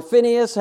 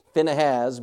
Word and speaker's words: Phinehas: Now, my point that I wanted to go to this passage for is, okay, Phinehas or Phineas, however you want to say his name, but by the pronunciation here, it's Phinehas,